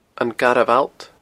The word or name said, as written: Garbh Allt